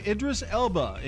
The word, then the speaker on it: Idris Elba: 1972, Idris Elba, English actor, is that how you say it? – Yes.